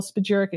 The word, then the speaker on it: spagyric: You know, Radical Roots, we take a different technique and we use an alchemical spagyric extraction, which isn't used by many.